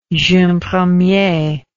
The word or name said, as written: jeune premier